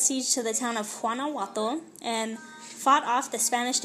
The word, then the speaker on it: Guanajuato: Guanajuato, and fought and fought off the Spanish defense of, it's probably Guanajuato. Guanajuato. Because I'm pretty sure it's a soft G.